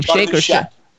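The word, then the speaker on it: Schaech: Jonathan Shake, who's been on our show twice. I don't know if that's how you pronounce his last name. – Shack. – Yeah. Jonathan Shack.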